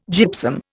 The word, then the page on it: gypsum: Indian